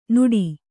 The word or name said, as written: ನುಡಿ